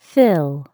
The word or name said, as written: fill